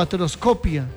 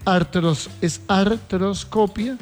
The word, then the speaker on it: artroscopia: Pero es que ninguna de dos, porque es artroscopia o artroscopía, vamos a ver.